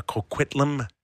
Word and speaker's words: Coquitlam: And he's from Coquitlam, B.C.